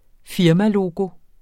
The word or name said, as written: firmalogo